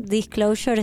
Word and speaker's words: Disclosure: ¿Está bien mi pronunciación? – Disclosure está perfecta.